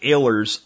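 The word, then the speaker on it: Oehler: And I'll put a link in today's show notes so you can see Mike Ehlers' underground house. And the name is Ehler, but it's spelled with an O. O-E-H-L-E-R.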